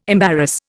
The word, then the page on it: embarrass: Indian